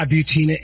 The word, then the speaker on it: Butina: Yeah, Butina, and it rhymes with subpoena.